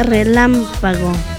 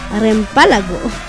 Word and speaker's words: relámpago: Ahí para Alan Hernández dice, pregúntale a la Telma cómo se dice relámpago. Hace rato dijo reempálago.